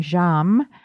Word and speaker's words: Jammes: People often ask me about the name Jammes. It's spelled J-A-M-M-E-S,